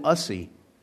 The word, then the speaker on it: ussy: They call it an ussy, rhyming with fussy.